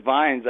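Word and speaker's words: Viñes: Is it Vines? Did I pronounce that right? – I pronounce it Vines. – Vines, okay. – I'm sure that if I spoke Spanish, it might come out a little bit differently.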